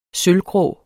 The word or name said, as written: sølvgrå